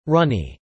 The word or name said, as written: runny